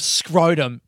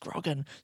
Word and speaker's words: scroggin: When I'm at work, when I need a little bit of a pick-me-up, I try a bit of scrotum. It's pronounced scroggin. Scroggin.